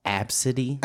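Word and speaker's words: Abcde: A, B, C, D. It's pronounced Absidy.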